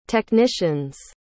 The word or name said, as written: technicians